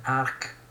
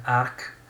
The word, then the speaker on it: Arch: quindi Frozen Arc, si scrive Frozen beh, come ghiacciato e Arc A-R-C-H,